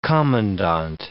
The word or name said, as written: commandant